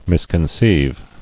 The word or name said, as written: Misconceived